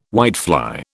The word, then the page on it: whitefly: British English